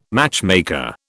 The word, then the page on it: matchmaker: British English